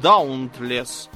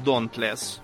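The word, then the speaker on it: Dauntless: это же дредноут Даунтлес. Для справки, э, это слово читается как донтлес по-английски. Я не знаю, для чего он живет в Америке, если он не, не способен выучить, как э, произносится буквосочетание AU.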